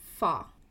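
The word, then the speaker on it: fa (发): hair is pronounced as fa and has the same pronunciation as fa in fa tai which means to be wealthy.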